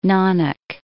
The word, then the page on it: Nanak: Na.nak